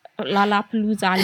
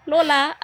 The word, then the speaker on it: Lollapalooza: I haven't seen anything in particular with the was it, how do you say that? Lollapalooza. – Lollapalooza.